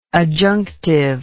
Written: adjunctively

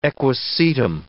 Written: equisetum

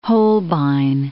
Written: Holbein

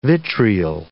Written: vitriol

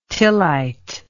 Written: tillite

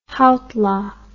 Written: heitler

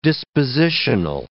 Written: dispositional

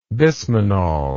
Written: bismanol

